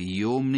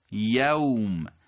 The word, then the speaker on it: یوم: یومیکم قبلا داشتیم مشابه این کلمه و قاعده رو، که بعضی ممکنه تو نماز هم بگن مالک یوم الدین، نه خیر یوم نیست، یوم، یوم الدین.